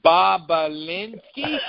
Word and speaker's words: Bobulinski: So the October surprise is named Tony Bobulinski. Really? Now, how goddamn original would that have to be? Tony Bobulinski. – It's Bob Ulinski. Bob Ulinski. – That's how it's spelled, B-O-B-U-L-I-N-S-K-I.